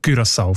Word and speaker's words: Curaçao: wo beide eben Curaçao vorschreiben. – Danke vielmals. Jetzt hast du mir den Namen von der Insel gesagt, wie man den ausspricht. Curaçao mit Ü in dem Fall.